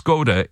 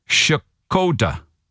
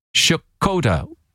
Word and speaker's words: Skoda: Apparently, the correct way to pronounce Skoda is Shkoda. Shkoda.